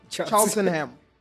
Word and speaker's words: Cheltenham: Cheltenham.